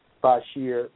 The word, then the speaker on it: Basheer: My name is spelled, well, you guys have it on the website, A-Q-U-I-L, Bashier, B-A-S-H-E-E-R.